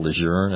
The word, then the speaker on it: Lejeune: General Lejeune, as the family pronounces it.